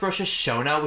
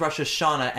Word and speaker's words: Rosh Hashanah: And one of the members of the chorus is like, it's actually pronounced Rosh Hashanah, which is why it rhymes with Arizona. And we're like, most people know it as Rosh Hashanah, and it's meant to be like a forced rhyme.